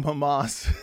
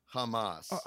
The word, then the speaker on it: Hamas: That's supposed to rhyme with Hamas. – Hamas. Their moms, Hamas. You know, it's a slant rhyme, but you know, I'll allow it.